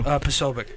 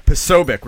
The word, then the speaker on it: Posobiec: How do you say his last name? I just taught you. – Posobiec. – Yeah, Posobiec.